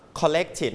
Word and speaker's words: collected: collect นะครับคำนี้ออกเสียงว่า collected นะออกเสียงว่า collected อันนี้จะเพิ่มมาอีก 1พยางค์เป็นเสียง it นะครับ